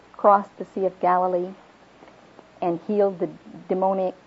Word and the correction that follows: demoniac: I can't say that word, demoniac, how do you say it, honey? Demoniac. Thank you.